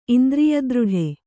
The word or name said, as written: ಇಂದ್ರಿಯದೃಢ